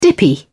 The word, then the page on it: dippy: British English